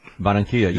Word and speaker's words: Barranquilla: And we're going to also go to Bogota and Barranquilla. And I hope I said that right. – Barranquilla. Yeah, that was very good. You did that well.